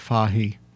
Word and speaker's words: Fage: It's spelled F-A-G-E. Trader Joe's sells it. – F-A-G-E? – Yeah, and in Greek it's pronounced Fahi.